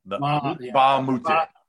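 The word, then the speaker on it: Mbah a Moute: The M is silent. Mbah-Amute.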